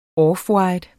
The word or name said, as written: offwhite